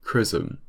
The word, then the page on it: chrism: British English